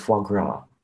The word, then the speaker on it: foie gras: It's titled Foie Gras. – Oh, very well pronounced, yeah.